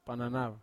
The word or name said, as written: pananau